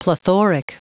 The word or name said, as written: plethoric